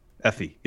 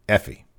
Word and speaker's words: Aoife: How do you pronounce her first name again? – Effie. – Yes, thank you. – Eva? – No, it wasn't Eva. – Effie. – Aoife. That's what.